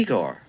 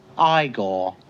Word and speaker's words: Igor: You must be Igor. – No, it's pronounced Igor. – But they told me it was Igor.